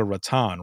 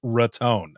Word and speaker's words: Raton: I said, "So I've never known the actual pronunciation of this city," and I said, "It's not Boca Raton, right?" And he goes, "Don't you ever say that again." He's like, "It's Raton."